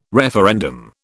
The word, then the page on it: referendum: British English